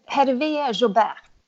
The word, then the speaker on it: Hervé Jobert: Her, Hervé Jambert och jag tror, fin... Her, Her, Hervé Jobert. Ja, det var bättre.